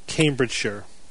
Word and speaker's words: Cambridgeshire: Although we've been informed, Evan, that it's pronounced Cambridgeshire.